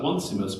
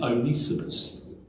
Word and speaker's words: Onesimus: Looks like Onesimus, but it's Onesimus. I've had to write that down phonetically, so I pronounce it correctly.